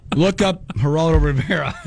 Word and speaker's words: Geraldo: Geraldo? Is that how you say his name? – Geraldo.